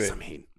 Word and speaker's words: Samhain: en particular en Samhain. Lo dije bien, ¿verdad? – Sí. – Samhain.